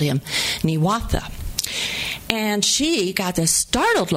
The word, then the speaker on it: Niwot: But it's not pronounced Niwot in Arapaho. It's pronounced, I say something like Niwatha.